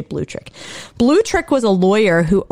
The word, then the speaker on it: Blutrich: Boltrich. – I would like to believe that it's Blutrich. – Blutrich. – Just like really That's, German like that.